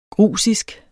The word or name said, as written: grusisk